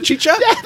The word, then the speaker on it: Chicha: Is that Chicha? – That's exactly how it's okay.